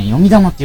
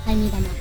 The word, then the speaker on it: 読み玉: トラック 6、これはね、読み玉って読みます。読み玉。タイミ玉。 – タイミ玉じゃないよ。読み玉ですよ。